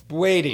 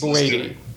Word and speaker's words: Boadi: I just need you to get the pronunciation on my last name right. Boadi. – Boadi. – Let's do it. – Kwame, yes, Boadi. Better? – Yeah. Yeah. Boadi is the anglicized version, and I'm fully woke now.